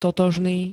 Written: totožný

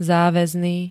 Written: záväzný

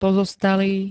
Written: pozostalý

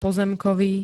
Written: pozemkový

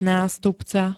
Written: nastupca

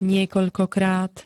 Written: niekoľkokrát

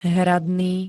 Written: hradný

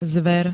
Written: zver

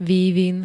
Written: vývin

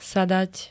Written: sadať

